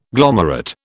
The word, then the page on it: glomerate: British English